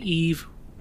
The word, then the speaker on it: Yves: Titled Adam and Eve, or I believe it's Adam and Eve. Mm-hmm. You don't pronounce the S because it's French.